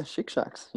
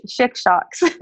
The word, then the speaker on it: Chic-Chocs: Yeah, Chic-Chocs. Yeah. – I listen to when you talk. Chic-Chocs.